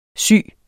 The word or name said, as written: sy